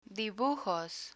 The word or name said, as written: dibujos